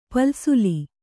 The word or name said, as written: ಪಲ್ಸುಲಿ